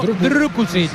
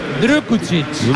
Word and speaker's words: Drukušić: Vanja Drukušić, internacional esloveno. – Repítelo. – Hay que pronunciarlo Drukušić. – Drukušić. Hay que poner la boca así, como si...